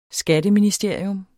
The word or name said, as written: skatteministerium